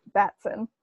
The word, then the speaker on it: Batson: And poor Billy Baskins. Am I saying his name right? – Batson. – Batson, that too.